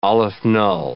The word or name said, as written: aleph-null